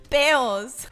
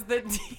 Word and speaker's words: pedos: Peos. – Los pedos. – No, cara. – Peos. – Peo is for Cubans and Caribbeans, but everybody else los pronounces pedos. Es los pedos.